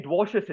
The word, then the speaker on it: Dwarshuis: But Ben Dwarshuis is in. That's how you pronounce his surname for um, all Delhi fans were probably scratching their heads about that.